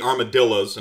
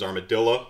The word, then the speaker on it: armadillo: You do, of course, have the armadillos, and I'm told that the proper pronunciation is armadilla, not armadillo.